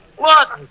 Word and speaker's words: Laughton: Wharton, Duffy. L-A-F-F-T-O-N. Yeah, Wharton. That's how the English spell it. Yeah, you know, like the London Times is pronounced the Thames.